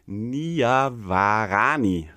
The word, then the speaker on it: Niavarani: wo der Michael Niavarani, so spricht man nämlich aus, äh, zu Gast war.